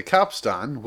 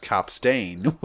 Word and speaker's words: capstan: It's like, yeah, the capstan, which also can be pronounced capstain, was started like, it's very, yeah.